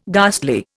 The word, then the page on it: ghastly: Indian